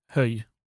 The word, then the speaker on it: Hög: Sen har vi då Hög i Skåne. Eller är det så man säger Höj? Höj. Ja, höj. Ja, det är det.